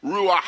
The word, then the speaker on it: ruach: And one of my favorite words in the Hebrew language that the Old Testament is written in, there's a word for spirit and wind that's ruach. But you have to say it like this. Ruach. Yes. Yeah. Get it in the back of your throat.